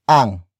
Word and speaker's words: ang: And then we have Ang, which is spelled as A-N-G.